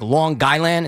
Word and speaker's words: Long Island: We kind of say it like Long Guyland instead of Long Island.